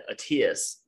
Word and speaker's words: Attias: Actually, it's pronounced Attias.